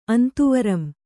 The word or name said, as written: ಅಂತುವರಂ